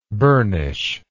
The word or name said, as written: burnishment